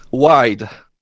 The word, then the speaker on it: WydD: So I designed it as WydD, like large.